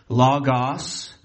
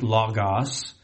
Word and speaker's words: logos: That's how it's pronounced in the Greek, logos. The Americanized version of that would be logos, and either one is fine. The second one sounds more Spanish to me, but they both work.